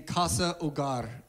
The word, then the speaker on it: Casa Hogar: On the flyer, you'll see this name, Casa Hogar. The H is silent.